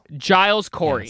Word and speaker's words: Giles: What is spelled C-U-M-I-N? – Okay, Giles. – Cumin. – Giles. – Giles Corey. – Yeah, soft front palate G. – Giles Corey. – Giles cool. Corey. – Today we're going to be talking about Giles Corey and the Essex County Curse.